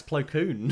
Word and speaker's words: Plo Koon: You can't even get his name right. It's Plo Koon.